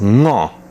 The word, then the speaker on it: Ngo: Oder es gibt eine Aussprachevariante für die Zahl 5, die heißt Ngo. Und dieses NG am Anfang auszusprechen, das ist am Anfang äh, ziemlich ungewohnt.